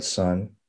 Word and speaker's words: Son: It's called Sun Sutra, and Sun is spelled S-O-N, but obviously has other meanings.